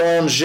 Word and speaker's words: Angers: Everyone's intrigued and listening, and then some guy chimes in, sorry to interrupt, but the city's pronounced Angers. Yeah, you drop the R and the S.